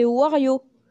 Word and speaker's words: Wario: C'est Wario. OK. – Tu l'as bien prononcé.